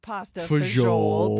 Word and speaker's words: pasta fagioli: So I think that the correct way to say it is pasta fagioli, but to make it a little more fun, just add that E at the end.